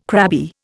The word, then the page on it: crabby: Indian